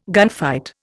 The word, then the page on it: gunfight: Indian